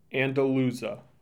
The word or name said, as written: Andalusia